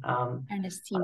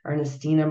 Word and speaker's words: Ernestina: It's Ernestina. Yeah, it's Ernestina Ernestina. – Malheiro. That's the English pronunciation. – Ernestina. – Otherwise, it's Portuguese, but I'll spare everyone the Portuguese pronunciation. Ernestina Malheiro.